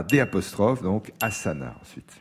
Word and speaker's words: asana: D'A'ASANAS, mais on prononce asana. Je rappelle la question. Dans quelle discipline parle-t-on d'asana? Asana.